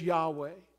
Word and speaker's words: Yahweh: It was so precious to the Hebrew people that they removed the vowels from the word, so we do not know exactly how it's pronounced. But most Bible scholars believe that it's Yahweh.